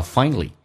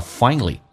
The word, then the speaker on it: finally: 第一个听力的难点叫 finally，美式发音少了个 a，因为 a 跟 l 在一起，美式发音是 a 会被 l 所吸收，所以叫到 finally， not finally。